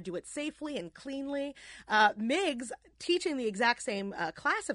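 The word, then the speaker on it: Meigs: And Dr. Miggs, on the other side, we're pronouncing it Miggs, but it's M-E-I-G-S, right? – Yes, I think that they pronounced it Meggs back Meggs.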